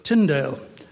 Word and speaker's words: Tyndale: I hesitated then because I just wanted to say that his name is William Tyndale, not William Tyndale, as you Americans might pronounce it. There's no such person as Habakkuk in the Bible or Isaiah. So it's Tyndale.